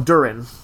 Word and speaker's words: Durin: Durin. – Durin. – Well, that's what it, Dor. I mean, Durin and Doran are different. – Fine, I mispronounced the U.